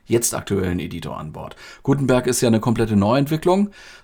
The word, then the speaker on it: Gutenberg: Diesen neuen Editor nennen sie Gutenberg oder Gutenberg, man darf das ruhig deutsch aussprechen. Den Buchdruck haben die Deutschen erfunden. Gutenberg.